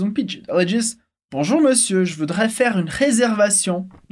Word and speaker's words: monsieur: De novo, lembrando que na palavra senhor a gente escreve monsieur, mas a gente não pronuncia como se lê, a gente fala monsieur. Ela repete comigo de novo. Monsieur. Sim, exatamente. São duas vezes o sonzinho E.